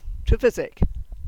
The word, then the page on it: Trevysek: Cornish